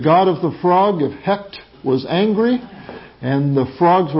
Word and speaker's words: Hect: Hekt, H-E-C-T is the name. In fact, some pronounce it Heka, but it's Hekt, H-E-C-T.